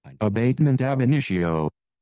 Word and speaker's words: abatement ab initio: Abatement ab initio.